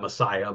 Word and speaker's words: Maaseiah: And then Azariah, the son of Messiah, Maaseiah. I'm going to pronounce that Messiah, but it's not Messiah, like Jesus Christ.